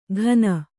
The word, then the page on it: ಘನ: ghana